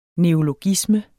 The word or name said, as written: NEOLOGISME